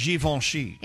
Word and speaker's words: Givenchy: Givenchy. – Is that how you say it? – Yeah.